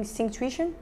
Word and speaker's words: Synctuition: I'm doing Synctuition. Sync. Sync tuition. So Synctuition is an application. How do you spell it? – Sync, like syncing. – Yeah, S Y N. – Tuition. Uh-huh Tuition.